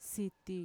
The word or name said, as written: siti